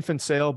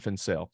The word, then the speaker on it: Van Zyl: And just for the listeners, it's Dean Fansale, but it's spelled V-A-N space Z-Y-L. So Dean Fansale.